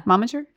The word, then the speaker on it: momager: He's been friends with the Kardashians for a while and approached Kim and her momager. Is that how you say it? – Yeah. – Momager? – I love it. And that's totally right.